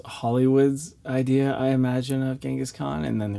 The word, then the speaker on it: Genghis Khan: Genghis Khan. – Yeah, Genghis Khan.